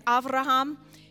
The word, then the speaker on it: Avraham: If you go back to what now I'm learning how to say their names right, you know, we learned Moses, it's Moshe. Abraham, it's Avraham.